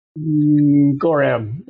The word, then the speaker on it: Gorram: Gorram.